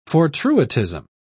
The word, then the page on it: fortuitism: British English